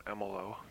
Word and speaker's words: Emolo: Okay, if you want to contact me, my name is Ron Emelo. That's spelled E-M-O-L-O.